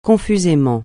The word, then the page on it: confusément: francais